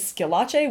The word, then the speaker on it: Schillace: And my last name is Schillache, which is spelled strange. It's S-C-H-I-L-L-A-C-E.